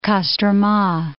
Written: Kostroma

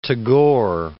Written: Tagore

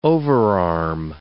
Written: overarm

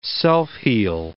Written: self-heal

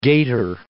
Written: gator

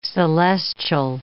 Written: celestial

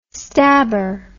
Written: stabber

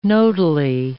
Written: nodally

